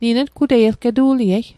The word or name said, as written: ngutei